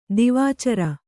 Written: ದಿವಾಚರ

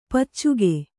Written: ಪೈಚುಕೆ

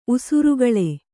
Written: ಉಸುರುಗಳೆ

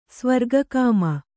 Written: ಸ್ವರ್ಗಕಾಮ

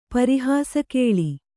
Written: ಪರಿಹಾಸಕೇಳಿ

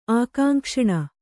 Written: ಆಕಾಂಕ್ಷಣ

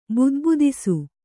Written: ಬುದ್ಬುದಿಸು

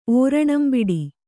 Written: ಓರಣಂಬಿಡಿ

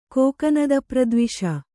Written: ಕೋಕನದಪ್ರದ್ವಿಷ

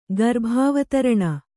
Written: ಗರ್ಭಾವತರಣ

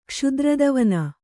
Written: ಕ್ಷುದ್ರದವನ

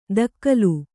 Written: ದಕ್ಕಲು

